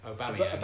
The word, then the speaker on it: Aubameyang: Pierre-Emerick Aubameyang. – Aubameyang.